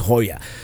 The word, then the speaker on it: Joya: You know, it's spelled J-O-Y-A, and it's pronounced Hoya, because you pronounce it as it would be in Spanish.